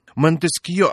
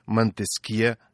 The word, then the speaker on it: Монтескье: Но кто, например, знает, что правильно говорить «Ришелье», а не «Ришелье», «Монтескье», а не «Монтескье», а великий русский математик Пафнутий Львович Чебышев на самом деле Чебышев.